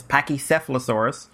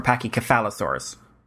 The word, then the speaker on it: Pachycephalosaurus: Um, so they're the family of dinosaurs, and you can pronounce, there's two different ways of pronouncing this Pachycephalosaurus or Pachycephalosaurus. I'm in Ireland, so I say Cafel, and uh, America says Cefal.